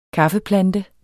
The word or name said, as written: kaffeplante